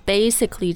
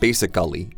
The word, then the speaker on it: basically: I think so 今天最后要跟大家说的呢是 basically 这个词的发音 Right, so actually here you might have heard a few times that we don't really emphasize the second A. So it's not basically, it's basically.